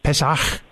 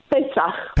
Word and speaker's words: Pesach: the, what you call help me with the pronunciation, uh, Pesach. Pesach. Pesach.